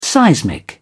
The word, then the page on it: seismic: British English